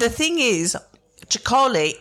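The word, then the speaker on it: Txakoli: Chicole. Do you know it? – No. – So it's Basque spelling. It's T-X-A-K-O-L-I or something. – Yeah. – But they sometimes spell it phonetically, Chicole. So C-H-A-C-O-L-I.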